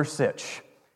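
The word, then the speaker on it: Sitsch: And I say, Schuler Sitch.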